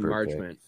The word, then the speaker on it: Marchment: So I was hoping for Mason Merchant or however you say his name. – Mason Marchment.